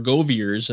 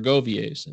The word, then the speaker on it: Goviers: You know, my father always said that we were Goviers and my mom said we were Goviers.